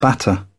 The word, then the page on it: batter: British English